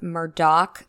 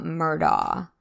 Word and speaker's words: Murdaugh: So first of all, the name is pronounced Murdoch, not Murdaw. If you hear a reporter saying the name without the k at the end, they're basically admitting to having no sources in Hampton County and not knowing what they're talking about.